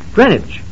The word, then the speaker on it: Greenwich: Greenwich. That's the name of the place. It's spelled as if it were pronounced Greenwich, but it's called Greenwich. – Yes, Eb, so it'll rhyme with spinach.